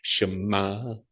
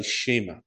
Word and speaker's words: Shema: Robert Ben instructed me on how to pronounce that. So you, if you can say it with me, Shema, Shema. You've got to say that Ma. Does that sound right? That'd be, yeah. It's not really right, but it's something.